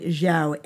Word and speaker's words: Xiao: Uh, th- there was a study done a few years ago by a Dr. Shan, I think I'm pronouncing his name right, Xiao, X I A O.